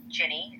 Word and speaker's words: Jinny: So my TikTok handle is WTF Jinny. play on the words, but it's spelled J-I-N-N-Y, my name.